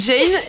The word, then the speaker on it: Gein: Donc je répète, il s'appelle Ed Gein et toi, ça s'écrit G-E-I-N.